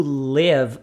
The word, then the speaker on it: live: Lembrando que o live aqui tem um som de E.